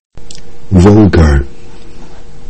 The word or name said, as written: vulgar